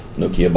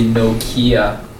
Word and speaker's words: Nokia: Nokia bought them, and it's pretty much Don't say Nokia. – It doesn't matter. – Focus on the first syllable. – Nokia. – Or some people say Nokia.